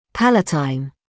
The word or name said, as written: palatine